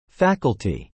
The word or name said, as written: faculty